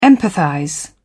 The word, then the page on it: empathize: British English